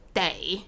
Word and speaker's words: 得: 第一个是 it's pronounced as day.